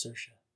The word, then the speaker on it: Saoirse: I'm pretty sure it's Saoirse. I watched like three videos how to pronounce that name.